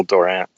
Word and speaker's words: Durant: Duran. Yeah, like Duran. – Yeah, Duran. – Like Duran. Duran. You say Bill Durant rhymes with Builderman.